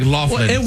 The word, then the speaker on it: Loughlin: L-O-U-G-H-L-I-N. That still sounds like Loughlin. – Well, I thought it was Loughlin, but no, I looked it up, and it says a lot of people mispronounce it, and it is actually Loughlin.